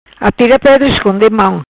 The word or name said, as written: Atira Pedra